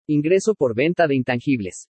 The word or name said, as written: INGRESO POR VENTA DE INTANGIBLES